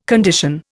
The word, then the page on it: condition: Indian